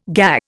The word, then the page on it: GA: Indian